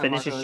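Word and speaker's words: Vinícius: was it Vinicius? Is that how you pronounce it? Yep, Vinicius Junior.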